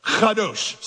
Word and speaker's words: kadosh: Kadosh. Say it. Kadosh. If the person in front of you is not wiping stuff off the back of their head, you didn't say it right.